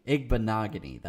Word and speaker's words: Igbinoghene: Igbinoghene? Igbinoghene. That's how you pronounce it.